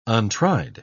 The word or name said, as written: untried